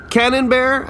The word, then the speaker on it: Camembert: Earl Camembert? Cannonbear, I should say.